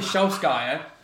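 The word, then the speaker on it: Solskjær: Ollie Shogars... Whatever, yeah. How have you pronounced his name? Yeah. Ollie Solskjaer. – Yeah, yeah, yeah.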